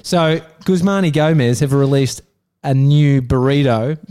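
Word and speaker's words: Guzman y Gomez: Guzman and Gomez have released a new burrito. – is that how you say it? – I think it's Guzmani Gomez.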